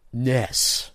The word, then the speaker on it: NES: Well, according to Nintendo, via the new WarioWare game, Nintendo, the NES is supposed to be pronounced Ness.